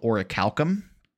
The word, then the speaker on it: orichalcum: orichalcum. And orichalcum? Orichalcum. It's spelled O-R-I-C-H-A-L-C-U-M.